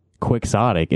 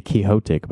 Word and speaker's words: quixotic: But every time I hear someone say the word quixotic, it's like with an X like that. – Yeah. – Quixotic. Well, I've never heard anyone say quixotic, but that's like how you say it, right? – Yeah, yeah. Well, I believe Don Quixote was a Spanish man. So right, yeah. – I think our English brains are not capable of pronouncing, you know, X's the same way.